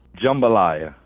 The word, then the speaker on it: jambalaya: Am I saying it right? Jambalaya? – Jambalaya. – Jambalaya. Okay. See, because I've been corrected on it the other way, but I trust you. – Right. It's jambalaya.